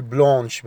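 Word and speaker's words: blanche: بس عاوز أقول بيضاني المؤنث أقول بلونش، بنزود أش أو، بلونش.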